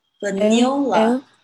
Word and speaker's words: vanilla: I know I see 对，但是因为它那个 i 的声音呢，不是 e 的声音，所以你不能念成 il，而是 il vanilla。